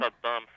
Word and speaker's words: Saddam: How is his name pronounced? Do you know? – Saddam Hussein is the way his name is pronounced.